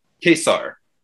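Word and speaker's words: Ka-Zar: And is it pronounced Kazar? I forgot. You corrected the way it said. Is it Kazar? – Kazar. – Mm-hmm. Yeah. – On the cover of X-Men 10, it says his name, and then right underneath it says, here's how to pronounce it.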